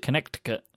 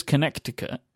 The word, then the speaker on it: Connecticut: But no one says Connecticut. Is that what? It's the C in the middle that's the problem.